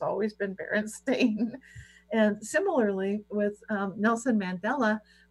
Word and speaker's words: Berenstain: Yeah, it was Berenstein for me. – Right. Right. And now apparently you could never pronounce it that way, because apparently it's always been Berenstain.